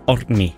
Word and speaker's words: Odni: Hvad var det rigtige? Må jeg lige få den? – Odni. Ja.